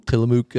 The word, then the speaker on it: Tillamook: Tillamook dairy.